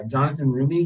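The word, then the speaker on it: Jonathan Roumie: Jonathan Rumi? Is that how you say it? – Yep.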